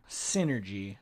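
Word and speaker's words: Psynergy: Synergy. – Synergy. – With a P in front. The okay. – P is silent. – Okay. – Like Psycho.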